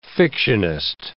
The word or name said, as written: fictionist